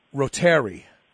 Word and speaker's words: reuteri: reuteri, depending on how you want to pronounce it. Reuter, like it's spelled almost like Reuters, R-E-U-T-E-R-I, that after a short exposure, they had two groups of rats.